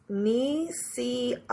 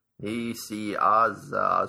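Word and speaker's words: Nicieza: I think Fabian's last name is pronounced Nisiazi. Nisiazi? – Nisiazi is what he says, so he might be right.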